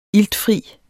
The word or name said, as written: iltfri